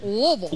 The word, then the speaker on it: Louisville: Don't say Louisville. – I like how you say it, probably. It's like you have peanut butter in your mouth. Louisville. – Louisville. That's how they tell you they have to say it.